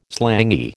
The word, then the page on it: slangy: American English